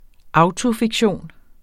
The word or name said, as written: autofiktion